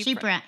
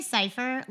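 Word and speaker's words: Chypre: Is it pronounced chip? Not chipper. – Oh, Sheepra? – Cypher? Sheepra. – Sheepra, yeah. This is, I used to say cypher, like a rap cypher.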